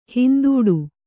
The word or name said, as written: ಹಿಂದಡಿ